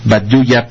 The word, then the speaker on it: Baduya: Bitsa, se pronunciará Bitsa, con muy griega, porque hace rato Baduya pasó y dije Badutsa, y en zapoteco es esa X es como una Y o Y, podría ser Baduya pasó, así como saludan en zapoteco los hermanos del Istmo.